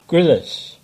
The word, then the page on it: Grillis: English